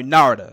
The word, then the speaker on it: Narada: I'm sorry, Narada, because it rhymes with Florida.